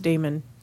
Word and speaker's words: Djimon: Yeah, because I'm assuming that D-J-I-M-O-N is pronounced Damon.